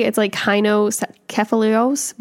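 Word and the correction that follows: kynokephalos: but kinocephalos.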